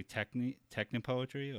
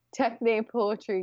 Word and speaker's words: techne poetry: I was wondering if you would mind reading some of your poetry, or now, am I saying this correctly? Technopoetry or techne poetry? Yeah, I think that's how you would pronounce it.